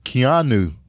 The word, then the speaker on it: Keanu: That's how you say it. Keanu.